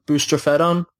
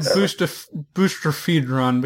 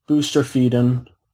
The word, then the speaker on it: Boustrophedon: Yeah, it's pronounced a particular way in Greek, but then we're English speakers, so... – I've always said Boustrophedron because I only ever saw it in writing.